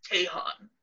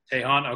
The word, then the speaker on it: Tahan: How do you say his last name? – You know, the truth is that even he will say it differently at times. He says Tahan. – Tahan, okay.